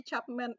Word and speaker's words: Chapman: Gary Chap apa sih bacanya Gary Chapman nggak sih? – Chapman. – Chapman.